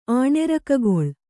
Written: ಆಣೆರಕಗೊಳ್

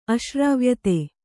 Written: ಅಶ್ರಾವ್ಯತೆ

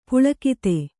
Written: ಪುಲಕಿತ

